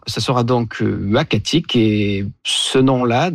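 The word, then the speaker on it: Uhackatic: Et donc, on s'est mis d'accord sur Wakatik, qui désigne un endroit propice à la chasse du caribou.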